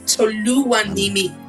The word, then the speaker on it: Toluwanimee: Yeah, so it's Toluwanimee.